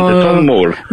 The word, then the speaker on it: Tom Moore: Ok, Wolf Walker di Tom Moore. Ho detto giusto, ho capito bene? – Moore, va bene. – Esatto, Tom Moore.